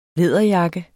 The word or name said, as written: læderjakke